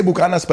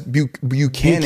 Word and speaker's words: Buchanan's: We say Bucanas, but it's not actually pronounced like that. It's Buchanan.